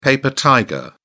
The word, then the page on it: paper tiger: British English